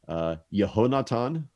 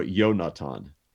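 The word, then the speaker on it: Yehonatan (יהונתן): So Jonathan's name in Hebrew would be pronounced either Yehonatan or Yonatan.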